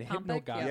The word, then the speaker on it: hypnagogic: Hypnopompic and hypnagogic.